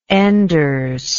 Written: enders

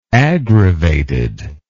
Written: aggravated

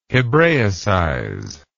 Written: hebraicize

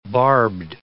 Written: barbed

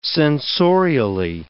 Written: sensorially

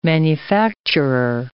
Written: manufacturer

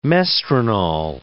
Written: mestranol

